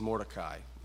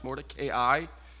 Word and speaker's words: Mordecai: And listen, I know the movie says Mordecai. My pronunciation marks put a long sound over the A and the I. Just trying to be faithful to my pronunciation marks. Mordecai. Appreciate the emails, but can't always trust the movies.